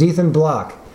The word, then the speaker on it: Ethan Bloch: Our special guest today is Ethan Block. Is that how you say your name? – That's exactly. – And it's spelled E-T-H-A-N-B-L-O-C-H? – Yep.